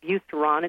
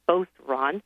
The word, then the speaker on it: Beauceron: You can listen to it and know that it's not Beauceron, it's Beauceron.